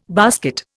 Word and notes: basket: Indian

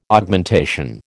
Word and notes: Augmentation: American English

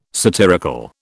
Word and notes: satirical: British English

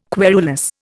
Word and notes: querulous: Indian

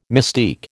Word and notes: mystique: American English

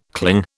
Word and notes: cling: British English

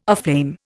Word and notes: aflame: Indian

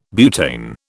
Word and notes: butane: British English

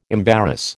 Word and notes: embarrass: American English

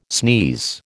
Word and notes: sneeze: American English

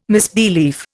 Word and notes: misbelief: Indian

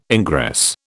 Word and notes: ingress: British English

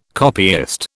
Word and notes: copyist: British English

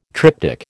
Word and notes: triptyque: American English

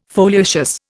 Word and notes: foliaceous: Indian

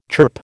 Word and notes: chirp: American English

